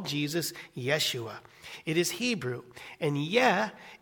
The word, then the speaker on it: Yeshua: The name Jesus in the Hebrew is pronounced Yeshua,